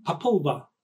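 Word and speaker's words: Popol Vuh: So now we go to the Popolva, I think is pronounced correctly, Popolva. Angelica's nodding her head, so yes, that's how you pronounce it.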